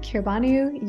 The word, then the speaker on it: Kirbanu: And under that same name, Kirbanu, you can find me on Apple Music, on Spotify, on Amazon, wherever you listen to music, on YouTube.